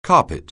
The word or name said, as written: carpet